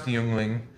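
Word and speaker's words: Jüngling: in 1829 by David Gottlieb Jungling, pronounced Yuengling, from Aldingen near Stuttgart, Germany, which I thought was kind of cool since we have a Stuttgart, Arkansas.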